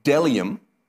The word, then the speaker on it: bdellium: Apparently, it's pronounced delium, and it's like a resiny thing.